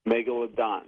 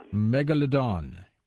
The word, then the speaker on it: Megalodon: Megalodon. – Megalodon, which is this prehistoric creature, the cousin of the modern-day great white shark.